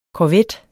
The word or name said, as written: korvet